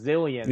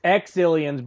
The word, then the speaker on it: Exilians: Yeah, yeah, it was weird because like the way it was spelled in like the subtitles, you'd think it would be pronounced zillions. – Yeah. – But it's exillions because they have it's just their name is X.